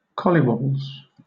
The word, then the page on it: collywobbles: British English